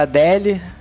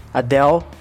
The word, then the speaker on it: Adele: Quem cantou foi a Adele. Adele.